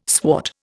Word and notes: swart: Indian

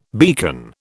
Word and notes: beacon: British English

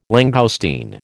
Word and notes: langoustine: American English

